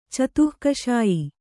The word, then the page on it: ಚತುಃಕಷಾಯಿ: catuh kaṣāyi